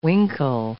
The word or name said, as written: winkle